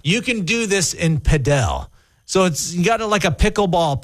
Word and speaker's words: padel: It looks like paddle, but it's pronounced padel.